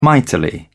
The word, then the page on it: mightily: British English